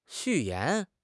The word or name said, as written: 序言